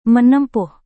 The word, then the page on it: menempuh: Indonesian